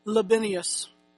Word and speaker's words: Libanius: I don't really know these words. And like Mark says, I wasn't there, so I don't know how they pronounce their names. But for us today, it's Libanius.